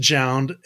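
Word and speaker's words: JJJJound: If that's how you're supposed to pronounce it's four J's. So it's pronounced. I did look into. – Is it just Jound? – It's just pronounced Jound, and it is. So it's there's four J's at the front of it, though. So yeah, it's Jound.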